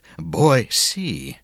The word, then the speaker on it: Boise: Yes, Boise.